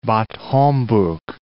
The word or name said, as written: Bad Homburg